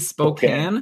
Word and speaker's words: Spokane: Apparently, I said Spokane wrong. It's supposed to be Spokane. – Okay. Yeah. – But it's spelled K-A-N-E. It's not Patrick Can.